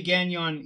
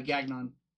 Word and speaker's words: Gagnon: It is Gagnon. If you say Gagnon, he will be tickled that you pronounce it as the French do, but he goes by Gagnon.